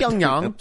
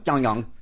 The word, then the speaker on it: Pyongyang: Pyongyang. – Pyongyang.